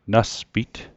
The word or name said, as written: naspit